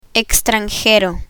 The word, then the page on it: extranjero: spanish